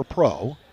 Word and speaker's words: Prough: Out of the top for Pro. Spelled P-R-O-U-G-H, but pronounced like P-R-O.